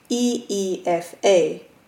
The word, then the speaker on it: Aoife: Dad said my sister's name was Aoife, spelt A-O-I-F-E, but pronounced E-E-F-A.